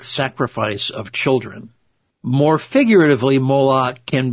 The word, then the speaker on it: Moloch: The names Maul and Locke, always spoken of in that order, are pronounced the same as the name Moloch,